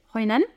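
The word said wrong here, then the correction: Hội An: Oh, enfin, en vietnamien, on dit Oyan.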